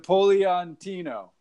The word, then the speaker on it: Napolitano: I'm like, bro, all you got to do is pronounce it like Spanish. He's yes. – Like, Napolitano.